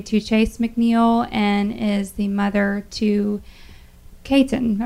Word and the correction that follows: Chayton: I hope I'm saying that right. – Chayton. Yes.